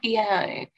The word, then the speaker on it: Eno: Well, Eno, it's like Eno.